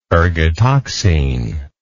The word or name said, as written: ergotoxine